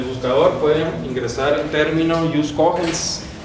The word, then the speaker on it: jus cogens: o jus cogens, quiero pronunciarlo en español.